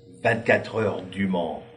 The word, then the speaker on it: 24 Heures du Mans: So there you have a brief histoire of, how do you say Le Mans 24 hours in French? Say that for me, please. – 24 heures du Mans.